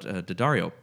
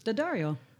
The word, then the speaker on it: D'Addario: Just to call out D'Addario, which apparently is pronounced D'Addario. Have you heard this? – D'Addario. – Okay, I thought it was D'Addario. – Oh. – Because it's spelled D'Addario. – D'Addario. But marketing people assured me it was D'Addario.